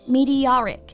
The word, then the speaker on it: meteoric: 是的，但是要注意啊，它从名词变成形容词，读音也发生了变化。那名词流星我们读的是 meteor, 重音在第一个音节，而形容词 meteoric 它的重音就到了第三个音节上了， meteoric.